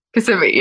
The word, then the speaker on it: Kissimmee: Kissimmee. Yep. – Kissimmee. Okay, it's not so much kiss.